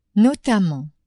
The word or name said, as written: notamment